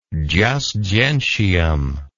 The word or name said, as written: jus gentium